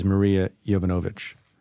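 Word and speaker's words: Marie Yovanovitch: First name I'll mention is Maria Yovanovitch. Did I get that right? – Yes. Well done.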